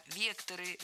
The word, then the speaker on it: векторы: Не вектора, а векторы, векторов, векторами. Всегда ударяем «е» в первом слоге. Это слово тоже имеет неподвижное ударение на основе.